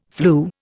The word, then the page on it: flu: Indian